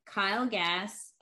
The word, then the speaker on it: Kyle Gass: You said Jermaine Dupri, MC Gainey, Kyle Gass.